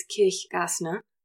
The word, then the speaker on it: Kirchgaessner: And Stephanie, well, I'm going to say her name German. It's Kirchgaessner. That's not how they say it.